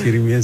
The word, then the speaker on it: chirimía: la chirimía, ¿verdad? ¿Sí lo dije bien? – Sí, es chirimía, sí.